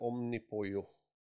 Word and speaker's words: Omnipollo: Eller Omnipojo, för er som bryr er. – Varför tittar du på Hagge? – Ja, det, de själva vill ju att man ska uttala det som pollo. – Polo. – Det, det spanska ordet för kyckling.